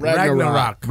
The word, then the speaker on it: Ragnarok: Ragnarok.